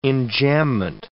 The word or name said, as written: enjambment